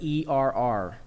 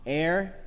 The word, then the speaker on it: err: Words that are often mispronounced, like the word ERR. What do you think? ERR. Air? I hear more airs. It's actually er.